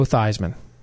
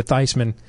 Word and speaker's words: Theismann: Lawrence Taylor comes off the edge and blows up Joe Theismann, who's really Joe Theismann, but he changed his name to Theismann, the pronunciation, while at Notre Dame, because he wanted to win the Heisman Trophy.